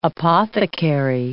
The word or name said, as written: apothecary